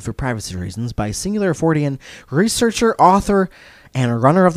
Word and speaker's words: Fortean: Fortean. Yep. It's pronounced.